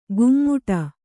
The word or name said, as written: ಗುಮ್ಮುಟ